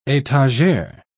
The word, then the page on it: etagere: British English